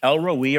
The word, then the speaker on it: El Roi: It's just one Hebrew letter different, but it's pronounced the same in English, El Roi or El Roe, the Lord our shepherd.